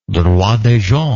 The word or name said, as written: droit des gens